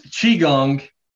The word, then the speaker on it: Qigong: It's Qigong is how you say it. It's a romanization of a Chinese word. So yeah, it's spelled kind of funny.